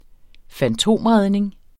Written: fantomredning

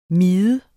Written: Miderne

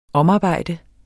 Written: omarbejde

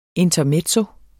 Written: INTERMEZZI

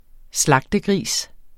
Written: slagtegris

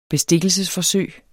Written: bestikkelsesforsøg

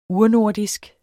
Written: urnordisk

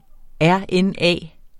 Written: rna

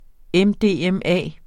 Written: mdma

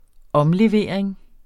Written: omlevering

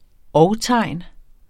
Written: og-tegn

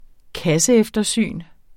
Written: kasseeftersyn

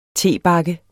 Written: tebakke

